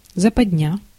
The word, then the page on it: западня: /zəpɐdˈnʲæ/